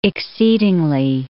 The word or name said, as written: exceedingly